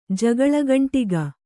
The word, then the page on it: ಜಗಳಗಂಟಿಕ್ಕು: kannada